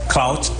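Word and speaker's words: clout: cloud chasing นะ cloud ตัว T นะออกเสียงด้วยตัว T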